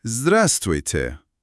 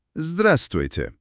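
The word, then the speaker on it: زدرستویته (Здравствуйте): زدرستویته. زدرستویته.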